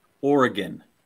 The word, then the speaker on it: Oregon: Oregon or Oregon? – Oregon. – Got it. – There is no Oregon.